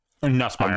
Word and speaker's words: Nussmeier: How the fuck you want to pronounce that? – Nussmeier.